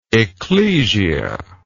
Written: ecclesia

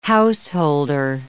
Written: householder

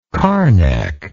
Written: carnac

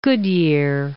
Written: Goodyear

